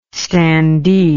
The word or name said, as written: standee